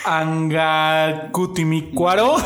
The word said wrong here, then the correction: Angamacutiro de la Unión: Arangaricutimiri. – No. a, Anga, ver. Angamacutiro de la Unión.